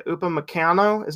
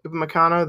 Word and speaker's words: Upamecano: I would like to see someone if they sign like, what do you say, Upamecano? Is that how you say it? Upamecano, the RB yeah.